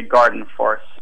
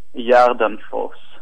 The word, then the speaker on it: Gardenfors: If you speak English, you can say Gardenfors. But in uh, Sweden, I say Jardenfors.